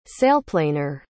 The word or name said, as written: sailplaner